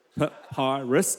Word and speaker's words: papyrus: But when she could hide him no longer, she got a papyrus, that's how you pronounce it, papyrus, correct, yep, basket for him, terrible word, and coated it with tar and pitch.